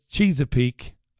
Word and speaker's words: Chesapeake: It's Chesapeake, as in cheese. Chesapeake, as in cheese, or Chesapeake, says Jenny.